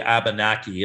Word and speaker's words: Abenaki: One of them is called the Abenaki, if I'm saying the name correctly. I believe it is correct. A-B-E-N-A-K-I, the Abenaki. The Pawtuxet, P-A-W-T-U-X-E-T. Again, P-A-W-T-U-X-E-T. And the Wampanoag tribe, W-A-M-P-A-N-O-A-G.